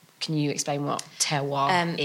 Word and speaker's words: terroir: I'm probably not pronouncing it right. Is it terroir? – Terroir.